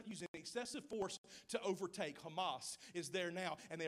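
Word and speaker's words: Hamas (חמס): It's spelled C-H-A, but when you say it in Hebrew, it says Hamas.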